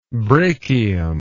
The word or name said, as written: brachium